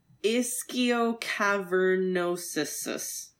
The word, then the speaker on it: Ischiocavernosus: So these pelvic bones attached to muscle groups called the, I'm going to butcher the pronunciation of this. Telly, you can correct me because you know muscles. Ischiocavernosus muscles. – Looks about right. – I think you just put an extra sus on the end, but other than that, it seems pretty good.